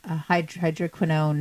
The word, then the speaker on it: hydroquinone: But I'm thinking, okay, but what about the PEGs, the glycols, the sulfates, hydroquinone? Did I say that right? – You did great.